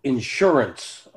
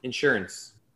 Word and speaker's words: insurance: He put the emphasis on the first syllable. I go insurance. I put the emphasis on the second syllable.